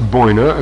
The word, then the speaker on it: Binu: It's been customary to derive the name of the phoenix from the Egyptian Binu, pronounced Boina, according to Case,